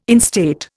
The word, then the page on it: instate: Indian